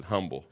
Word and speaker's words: humble: Some people pronounce it humble. I don't know why the H is silent there, but I pronounce it humble.